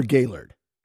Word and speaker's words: Gaylord: Is it Gaylord or Gaylord? – Gaylord. – I mean, I go Gaylord. – Yeah, it is. Yeah, most of the locals will say Gaylord. – Even though it's spelled Gaylord.